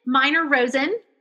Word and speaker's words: Miner-Rosen: Okay, Lynn Miner-Rosen.